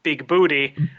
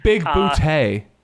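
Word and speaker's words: Bigbooté: Christopher Lloyd plays the character John Big Booty. – Big Bootay. – He always insists that his name is pronounced Big Bootay.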